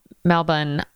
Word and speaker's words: Melbourne: Melbourne.